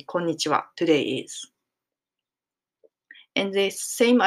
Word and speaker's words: konnichiwa: But konnichiwa, when you write it down, if you know hiragana, we write it down ha, konnichiha, as hiragana. But we pronounce konnichiwa. And the reason why it's happened is because wa is a particle.